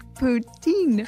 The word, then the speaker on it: poutine: Poutine.